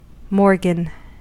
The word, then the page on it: morgan: American English